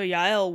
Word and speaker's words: Yael: So if you are not familiar with that story, if you also have not been exposed to that story, it's either J-A-E-L or Y-A-E-L, just depending on how it's spelled. In Hebrew, it's Yael.